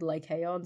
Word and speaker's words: Lycaon: The one that said, I think this might be modern Greek, but they said Lycaon, so I'm going to go with the modern Greeks.